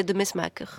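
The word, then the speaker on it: De Mesmaeker: Bah, moi, je disais de Messmaker. – Ah, oui. Comme tous les Français. – Et alors, en Belgique, c'est de Messmaker.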